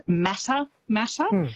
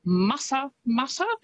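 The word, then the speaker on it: Matamata: Quite often, because of how it's spelt, people will pronounce it Mata Mata, but it's Mata Mata, which is, I think, a lovely way to say it.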